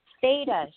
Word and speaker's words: theta: And you are a theta healer. Am I correct? – Yes. – Okay. – And for anyone who's wondering what that is, it's spelled T-H-E-T-A. – Yes, theta.